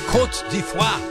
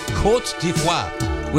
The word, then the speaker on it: Côte d'Ivoire: It is Germany versus Côte d'Ivoire. That's how you say it. I looked it up. Côte d'Ivoire.